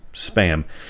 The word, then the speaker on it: Spa'am: Now, it's spelled like ma'am, SPA'AM, but the chief pig's name is Spam.